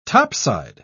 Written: topside

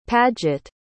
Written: padgett

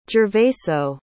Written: gervaso

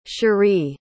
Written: cherie

